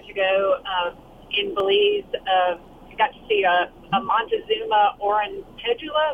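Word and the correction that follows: oropendola: Am I saying it right? – Or a pendula. – That's it? – Yep.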